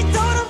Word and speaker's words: told him: So the him there is pronounced as an M. So the H is dropped. And I join the M with the D for told. Told him, like a flap sound.